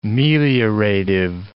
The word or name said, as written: meliorative